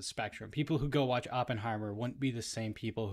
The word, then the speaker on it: Oppenheimer: Yeah, Oppenheimer.